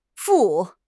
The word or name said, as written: 副